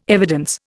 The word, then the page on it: evidence: Indian